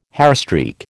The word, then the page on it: hairstreak: American English